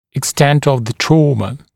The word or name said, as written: extent of the trauma